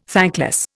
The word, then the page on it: thankless: Indian